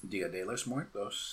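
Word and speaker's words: Día de los Muertos: Dia de los Muertos? – Dia de los Muertos.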